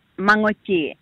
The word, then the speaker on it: Mangochi: It's called Mangochi, the tail end. The tail end sound is nga nga nga.